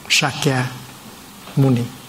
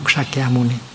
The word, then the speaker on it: Sakya Muni: Bây giờ mình phải đọc lại cho đúng. Sakya Muni, Bụt Sakya Muni, đó là tên đúng của Ngài.